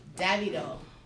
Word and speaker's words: Davido: And last but not least, we're going to play your favorite R&B hits from Anthony Hamilton to Davido.